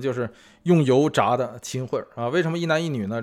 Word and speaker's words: 秦桧: 就是秦桧的桧啊，